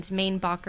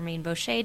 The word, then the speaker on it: Mainbocher: They had the Paris fashion brand, Mainbacher or Mainbocher, depending on how you want to pronounce it, designed the first Waves and Spar uniforms.